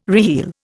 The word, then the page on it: reheel: Indian